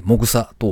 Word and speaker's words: MOXA: サイトを見ていてちょっとびっくりしたんですけど、 MOXA と書いて、もぐさと読むみたいですね。